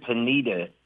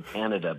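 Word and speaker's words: Panida: And don't say the word Panita. It doesn't rhyme with Anita. So come out to the Panada because it rhymes with Canada.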